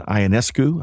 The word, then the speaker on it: Ionescu: So for folks that may be listening out there, it's Sabrina Ionescu, I think I said that right, Kelsey Plum, Caitlin Clark, and Diana Taurasi.